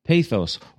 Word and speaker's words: pathos: And this in the ancient and original Greek was called pathos, P-A-T-H-O-S, pathos. Or perhaps in Greek, one of my Greek students once said to me, you know, it is more correctly pronounced in our language as pathos. I thought, okay, fine. So pathos or pathos, whichever you prefer is fine.